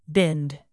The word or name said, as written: binned